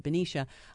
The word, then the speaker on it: Benicia: Am I pronouncing that right? Benicia? – Benicia.